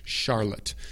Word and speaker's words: Charlotte: Se pronuncia de la misma manera que Chicago. Chicago. Chicago. Es con C-H. C-H. Pero suena como si fuera SH, ¿me entienden? Charlotte, Chicago, ¿ok? Pero Charlotte se deletrea C-H-A-R-L-O-T-T-E. C-H-A-R-L-O-T-T-E.